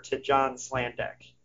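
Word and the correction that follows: Sladek: Slaydek. – Of course I pronounced it wrong.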